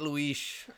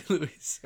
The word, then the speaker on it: Luis: Also, it's spelled L-U-I-S. It's okay. Totally Luis, right? – Yeah, why are you saying Luis? – Because that's how you do it if it's Portuguese. So I just say it like that because I like the pronunciation.